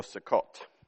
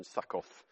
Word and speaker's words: Sukkot: And in that month, the seventh month back, they kept the Feast of Tabernacles, also known as Booths or Sukkot, or if you don't know the Hebrew pronunciation, Sukkoth.